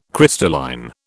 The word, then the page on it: crystalline: British English